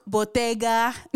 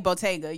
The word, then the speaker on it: Bottega: She came over there dressed in her silk bottega. You got to say it like Bia. Anytime you can't just say bottega. You got to say bottega.